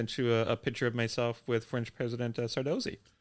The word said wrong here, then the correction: Sarkozy: Did you say Sar- Sardozy? – How, how do you pronounce it? – Sarkozy.